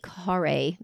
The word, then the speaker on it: Carré: And the factory here had machines that turned out 5 to 6 tons of ice per day using the Carré method. I'm probably saying that wrong. It's C-A-R-R-E. But Ferdinand Carré was French,